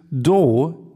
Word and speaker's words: Douyin: Das letzte Unternehmen, was ich mitgebracht habe, ist ähm, Doujin. Do, nee, jetzt, warte, jetzt korrigiere ich mich selber, weil ich gemerkt habe, dass es nicht richtig ausgesprochen wird. Das letzte Unternehmen ist Doin die Firma, die äh, oder das Pendant, das chinesische Pendant zu TikTok.